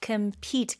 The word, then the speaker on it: compete: Compete, 강세가 pete에 들어가요.